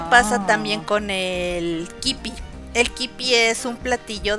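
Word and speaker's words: kibbeh: Eh, se puede, el kipi crudo, que se puede comer como un tipo carne tártara, y se pronuncia kipi, pero, bueno, aquí así lo decimos, pero la pronunciación como tal es kebe.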